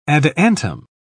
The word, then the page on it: adiantum: British English